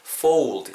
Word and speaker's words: fold: fold, fold 너무 이렇게 정직하게 발음하시기보다는 fold. 약간 중간에 우 사운드 들어간다 생각하시면서 발음하시면 훨씬 더 자연스러울 것 같습니다.